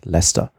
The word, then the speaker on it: Leicester: Leicester ist Leicester.